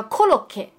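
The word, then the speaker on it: コロッケ: 因为它的日文叫 koroke，所以它听上去有点像可乐饼那个发音吧，然后它就被叫成了可乐饼。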